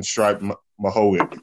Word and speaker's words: Miocic: And we have UFC 252 with a pretty good card and the World Heavyweight Championship between Daniel Cormier and Stipe Miocic. Actually, how do I say that name correctly? – Stipe Miocic. – Stipe Miocic. Miocic.